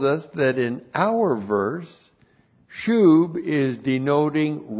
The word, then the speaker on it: shub: S-H long U-B, shub.